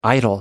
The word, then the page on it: idol: American English